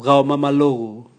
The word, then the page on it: gao mamalogu: Raga